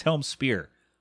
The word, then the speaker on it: Helmsphere: Helmspear says, is it Helmsphere? I think he said it's Helmspear, even though it's spelled sphere.